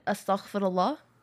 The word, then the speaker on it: Astaghfirullah: Astaghfirullah. – I'm trying. – I know, it's hard. It's because the la is a hard note for people to hit if you didn't grow up with it.